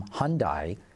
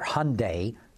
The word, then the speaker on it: Hyundai: Speaking of marketing people, we saw another change at General Motors of them stealing yet another executive away from Hyundai, or Hyundai.